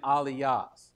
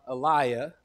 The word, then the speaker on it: aliyah: And I want to pronounce aliyah, aliyah, because I'm a Texan and that's what it looks like to me. But the better pronunciation, even though I'm probably still butchering it, is aliyah.